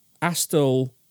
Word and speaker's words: Asthall: Now, Astle is, again, you can check it out on your maps. It's spelled A-S-T-H-A-L-L, and it is between the towns of Witney and Burford, and it's down the A40, so it's a well-travelled route.